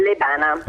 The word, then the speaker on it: Lebana: Dove ha chiamato, per esempio, Lebana, non so esattamente come si pronuncia, dalla provincia di Monza Brianza. Buongiorno. – Buongiorno. – Buongiorno. Ci dica come si pronuncia il suo nome. – Lebana. – Perfetto, certo. Lebana.